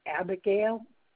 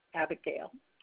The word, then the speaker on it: Abigail: First name is Abigail. I'll spell that. It's A-B-I-G-A-I-L. Abigail.